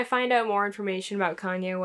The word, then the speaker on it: Kanye: And then the the lady's like, this is Kane. And he's like, it's Kanye. He's like, oh, well, we pronounce it Kane around here.